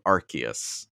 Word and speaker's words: Arceus: Pokemon has changed the pronunciation to Arceus.